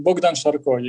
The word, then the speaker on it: Bogdan Sharko: Bogdan Sharko.